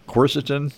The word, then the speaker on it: quercetin: And then I'm taking glucosamine, I'm taking quercetin.